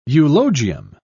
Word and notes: eulogium: British English

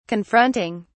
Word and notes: confronting: British English

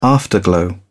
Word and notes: afterglow: British English

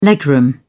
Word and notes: legroom: British English

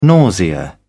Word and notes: nausea: British English

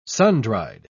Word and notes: sundried: British English

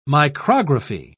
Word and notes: micrography: British English

